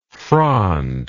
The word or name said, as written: frondless